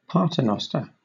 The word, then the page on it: paternoster: British English